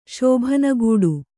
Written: ಶೋಭನಗೂಡು

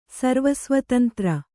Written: ಸರ್ವಸ್ವತಂತ್ರ

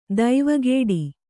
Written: ದೇವಕೂಟ

